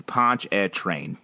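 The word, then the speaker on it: Pontchartrain: Ponch-a-Train. Don't pronounce the A or R and pronounce it like eh.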